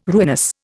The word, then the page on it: ruinous: Indian